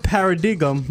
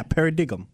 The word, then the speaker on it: paradigm: The word paradigm means a widely accepted belief, theory, of way of doing things. Yeah, paradigm. – Yeah, that's paradigm. – Paradigm. – The G is silent. – Dime. That's D-I-G-M.